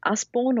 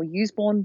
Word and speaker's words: Usborne: It was in one of those Usborne or Useborne books, however you want to pronounce that, that came out of the 1970s, The World of the Unknown.